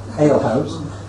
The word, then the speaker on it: ale house: That's one that was definitely an aged hale house. Oh, said it wrong there, hale house. Ale house.